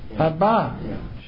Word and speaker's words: Abba: And I have to tell you, it was a little girl in Israel running down the street saying, Abba, Abba. She emphasized the second syllable.